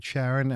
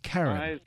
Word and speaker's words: Charon: And now, this might be obnoxious of me because you said Charon, and I love the fact that you actually know the name of Pluto's biggest moon, and it's spelled Charon, but it's pronounced Karen, like the girl's name Karen.